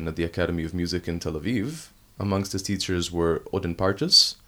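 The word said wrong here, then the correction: Ödön Pártos: Did I say that correctly? Odin Partosh. – Udin Partosh. – Udin Partosh. In English, it doesn't make any sense.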